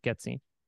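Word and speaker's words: Getzien: Pretzel Getzine, that's how you say it, Getzine.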